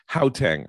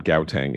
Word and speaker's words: Gauteng: That was in the first news story about its isolation in Gauteng, I'm told is how it's pronounced, not Gauteng, in South Africa.